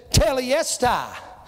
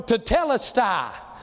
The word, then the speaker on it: Tetelestai: That day on Calvary, He said, Telestai, or Tetelestai. Depends on who you listen to, how they pronounce it. Telestai is the way that Brother Bob Daugherty always pronounced it.